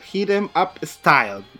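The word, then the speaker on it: Hit 'Em Up Style: Oigan, entonces nos vamos al lip sync del capítulo, donde tenemos a Candy versus Tamisha interpretando Hiremep Style. Lo dije mal. Hit em up style.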